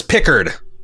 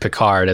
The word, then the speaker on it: Picard: He has reestablished Jean-Luc Picard as the way to pronounce his Frankenstein, not Frankenstein.